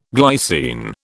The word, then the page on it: glycine: British English